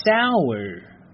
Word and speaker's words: sour: 这个单词呢叫做，来和我一起念 sour。注意啊，如果你是练的美式的发音呢，结尾呢需要去卷舌一下，因为它呢最后是 r 这个字母。